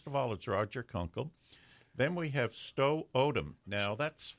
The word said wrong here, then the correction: Stu: pronounces it Stu.